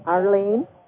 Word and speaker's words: Arlene: Arlene.